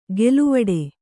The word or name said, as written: ಗೆಲುವಡೆ